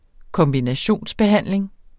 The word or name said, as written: kombinationsbehandling